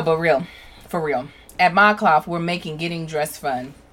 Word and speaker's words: ModCloth: They're called Mod Cloth. I was about to say Mod Clothed, and then I was like, that don't make no sense.